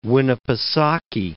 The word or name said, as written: Winnipesaukee, Lake